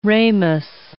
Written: ramus

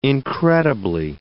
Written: incredibly

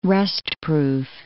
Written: rustproof

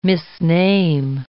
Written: misname

